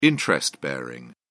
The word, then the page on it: interest-bearing: British English